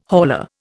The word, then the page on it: hauler: Indian